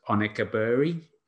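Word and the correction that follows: Okenabirhie: is the name. – Okeneberi. – Okeneberi. I was close.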